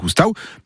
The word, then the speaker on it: oustau: Donc, la fin du mot oustau s'écrit AU en provençal, et ça ne se prononce pas O, mais AU.